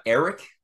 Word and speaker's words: Ehret: Oh, it's it's like uh, Eric, but with a T instead of a a uh, K.